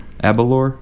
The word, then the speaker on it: Aberlour: How do you pronounce that? Aberlour. – Aberlour. – Aberlour. Yep. Abba.